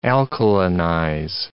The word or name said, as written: alkalinize